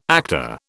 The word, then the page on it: actor: British English